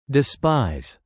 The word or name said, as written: despise